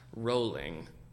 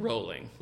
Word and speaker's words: Rowling: At Sarah Keeler B says she was trying to resist, but it's pronounced rolling, like bowling. And we both said J.K. Rowling. So, just beware. – Rowling. So it's J.K. Rowling. – Rowling, like bowling.